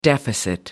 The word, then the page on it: deficit: American English